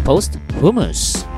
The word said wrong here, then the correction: posthumous: Humus? Posthumous. Oh, posthumous. It's supposed to be like posthumous.